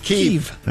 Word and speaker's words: Kyiv: Most of us said Kyiv, right? All along. Now it's Kyiv. – It's Kyiv.